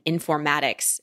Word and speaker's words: informatics: When I went to college, I didn't have an informatics, is that how you pronounce it? That's it.